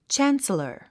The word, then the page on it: chancellor: tʃǽns ə lə r; チャンサラァ